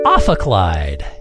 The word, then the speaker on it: Ophicleide: The word for our first round today is Ophicleide. Ophicleide. That's spelled O-P-H-I-C-L-E-I-D-E. Ophicleide. – Like, I won $20 off of Clyde? – Yes.